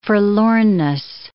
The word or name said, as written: forlornness